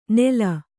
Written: ನೇಲ್

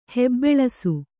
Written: ಹೆಬ್ಬೆಳಸು

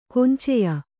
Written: ಕೊಂಚೆಯ

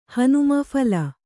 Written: ಹನುಮನ ಫಲ

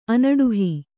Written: ಅನಡುಹಿ

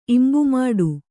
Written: ಇಂಬುಮಾಡು

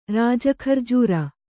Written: ರಾಜಖರ್ಜೂರ

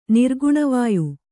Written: ನಿರ್ಗುಣವಾಯು

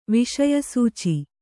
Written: ವಿಷಯಸೂಚಿ

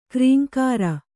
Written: ಕ್ರೇಂಕಾರ

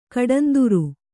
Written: ಕಡಂದುರು

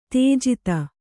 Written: ತೇಜಿತ

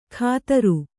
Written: ಕದರು

